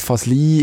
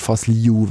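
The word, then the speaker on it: Fazliji: Und er hat uns gesagt, Fasli ist die schweizerdeutsche Aussprache eigentlich, so die akzeptierte, ist auch okay, wenn man es so sagt, weil sie so im Schriftbild nachher sich orientiert. Fasliu wäre ganz korrekt.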